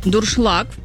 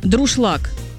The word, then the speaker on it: дуршлаг: Юля написала нам, что ее бесит, когда говорят неправильно слово «дуршлаг». «Дуршлаг», правильно? Многие произносят как коверка «друшлаг».